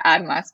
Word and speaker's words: Armas: Yes, Kat Armas is great, but in Spanish it's Armas, so Kat Armas, but yes, Kat Armas is also great.